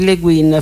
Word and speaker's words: Le Guin: perché pare si pronunci Le Guin, fra l'altro, almeno così ho visto.